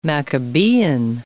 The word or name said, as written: Maccabean